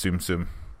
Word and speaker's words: Tsum Tsum: I'm guessing it's Tsum-Tsum. – Yeah. – It's like tsetse fly or something. – Yeah. – T-S-U-M, T-S-U-M.